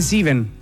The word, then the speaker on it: sieben: Lo que pasa es que sieben se dice sieben.